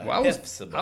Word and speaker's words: Hephzibah: So you got the PH where it's a Hephzibah. – I was today years old, like just now realizing we don't say the P.